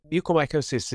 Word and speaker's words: mucormycosis: It's been causing this infection called black fungus, or I think it's pronounced mucormycosis, which was a problem in India. – So mucormycosis has been known for a long, long time.